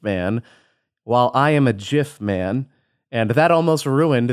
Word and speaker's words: GIF: I will fully admit that JIF is the correct term. The guy who invented it has said it is JIF. It's a hard J sound.